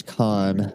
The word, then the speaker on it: Kahn: Oh, okay, so this guy James Kahn, I believe is how you say his last name.